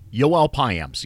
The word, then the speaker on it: Yoel Payamps: Was it Noel Biamps? Am I saying that right? Now he is. Yoel Payamps, yes. Anyway. Looks like Payamps.